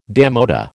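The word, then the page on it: démodé: American English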